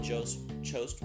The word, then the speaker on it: Jost: I thought the way that Michael Che and Colin Jost were... I always pronounce his name wrong. Jost. J-O-S-T.